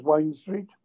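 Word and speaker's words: Wind Street: What we've been doing, there's one street, spelt Wind, that pronounced as Wine Street.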